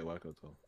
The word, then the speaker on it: Waikato: Yeah, Waikato.